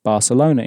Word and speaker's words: Barcelona: I should mention that you have two options regarding how to pronounce Barcelona in Spanish. And this works according to what I like to call the Cecilia rule. The Cecilia rule states that a letter C in front of an E or an I in Spanish is pronounced like an S in Latin America, but like a th, as in think, in most of Spain.